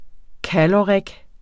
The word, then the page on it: caloric: ˈkalʌʁεg; ˈkalʌʁig